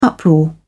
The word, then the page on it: uproar: British English